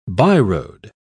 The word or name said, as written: byroad